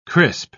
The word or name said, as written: crisp